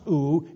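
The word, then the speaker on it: ou (οὐ): It is pronounced ou in the Greek, and again it underscores an absolute negative.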